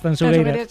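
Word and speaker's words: Tanxugueiras: A ver, repítemelo, como tengo que pronunciarlo bien. – Tanchugueiras, Tanchugueiras. – Sí, esto me lo corrige Fermín Agustín mucho. Marta, por favor, digo, mira que yo siempre pronuncio... A ver, otra vez, dímelo. – Tanchugueiras. – Acaba de deshacerse la manifestación a las puertas de la Gran Vía, de lo bien que lo has dicho.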